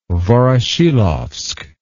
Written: Voroshilovsk

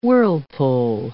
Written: whirlpool

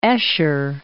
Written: Escher

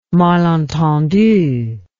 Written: malentendu

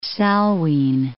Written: Salween

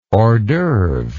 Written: horsdoeuvre

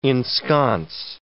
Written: ensconce

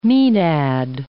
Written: maenad

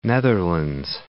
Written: Netherlands

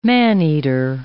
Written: man-eater

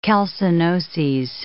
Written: calcinoses

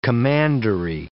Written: commandery

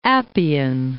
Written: Appian Way

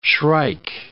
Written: shrike

